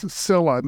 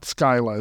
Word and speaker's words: Scylla: So the particular pronunciation that you prefer, though, is Scylla, not Skyla.